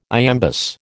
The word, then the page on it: iambus: American English